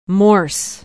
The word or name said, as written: morse